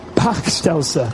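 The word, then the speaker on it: Bachstelze: Welschnascher? Is that quite right? It's pretty rubbish pronounced here. Pachtelser. Thank you very much. Better.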